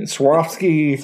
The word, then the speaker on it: Swarovski: I think she was referring to this wine cave has a famous, how do you pronounce Swarovski? Swarovski. Yeah, Swarovski crystal chandelier that's apparently famous.